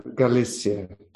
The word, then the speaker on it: Galicia: Galicia. – Okay. – Galicia.